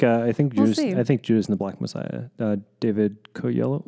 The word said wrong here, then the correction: Kaluuya: Oh, I don't want to butcher his last name. – Kaluuya, I think.